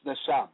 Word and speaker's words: neshama: The Hebrew word for breath in this text of scripture is neshama, pronounced neshama, meaning to puff, wind, angry, or vital breath, divine inspiration, intellect, breath, inspiration, soul, spirit.